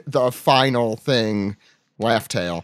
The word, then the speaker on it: Laugh Tale: People keep pronouncing this wrong. It's supposed to be Laugh Tale.